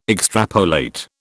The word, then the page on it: extrapolate: British English